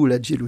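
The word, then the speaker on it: Ola Gjeilo: Alors, il vient d'où, Ola Jello? – Alors, il se prononce Ola Jello.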